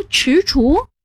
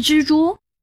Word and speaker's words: 踟蹰: 那两个字读踟蹰，不是蜘蛛？ – 踟左边是足字旁，右边是知道的知，也难怪你读作知，正确读音是踟，而蹰左边也是足字旁，右边是厨师的厨，读作蹰。